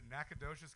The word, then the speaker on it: Nacogdoches: You know, I actually tried to do this in Nacogdoches and bombed 3 times in a row. – Nacogdoches, that's what it's Nacogdoches.